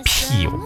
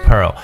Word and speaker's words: pearl: pearl 这个词呢是珍珠，p e a r l，pearl 这个词千万不要读错，因为我发现有很多同学呢说到珍珠就喜欢读成 peel，它不叫 peel，alright，peel 是 p e e l，这个叫 pearl，先发长音 a，OK，p e a r l，珍珠的意思 pearl，所以说到这个珍珠母叫 mother of pearl。